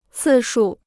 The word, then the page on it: 次数: cì shù